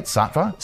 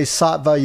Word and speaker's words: Sattva: But we were talking off air about how this word Sattva is pronounced differently in India. – Yes. Thanks for having us. – Absolutely. Absolutely. So how else is this? Am I saying it right? Sattva? – So we say Sattva Yoga and Wellness Center. – Okay. – But in India, many of the teachers say Sattva.